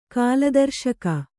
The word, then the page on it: ಕಾಲದರ್ಶಕ: Kannada